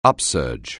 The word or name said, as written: upsurge